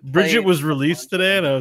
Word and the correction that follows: Brigitte: Isn't it Brigitte? – It's Brigitte, yeah.